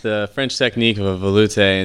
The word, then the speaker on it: velouté: When the chorizo, how do you say it? Velouté? – The velouté, yeah.